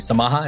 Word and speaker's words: Samaha: You meet Moon Equities, hopefully I'm pronouncing this correctly. Samaha, is that, yeah. Am I saying that correctly? – Yeah, and, Samaha.